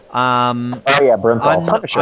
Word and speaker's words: Bernthal: Bernthal.